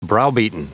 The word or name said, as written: browbeaten